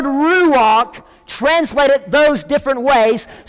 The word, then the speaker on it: ruach: And the tag said, RUACH. Coming up I-65 from Prattville to Clanton. The car beside me comes speeding by about 95 miles an hour. R-U-A-C-H. It's a Hebrew word. It's pronounced ruach.